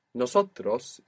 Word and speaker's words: nosotros: You pronounced an S there, which was very good. Most English speakers find themselves accidentally saying nosotros with a Z. Not nosotros, but nosotros. It's an S.